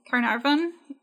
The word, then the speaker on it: Caernarfon: And the town currently is spelled with an F instead of a V. So it would be Caernarfon. That's the Welsh spelling. A single F has a V sound.